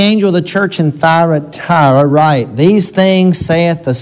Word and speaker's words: Thyatira: This letter to the church of, Thyatira, I looked it up. It's actually four syllables. I guess some people could say Thyatira.